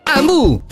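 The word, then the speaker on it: Hamu: Amu si scrive con l'H.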